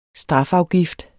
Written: strafafgift

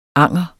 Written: anger

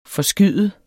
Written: forskyde

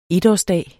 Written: etårsdag

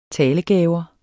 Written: talegaver

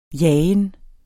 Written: jagen

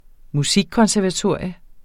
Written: musikkonservatorium

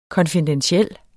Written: konfidentiel

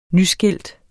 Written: nyskilt

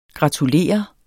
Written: gratulere

